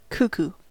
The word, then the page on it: cuckoo: American English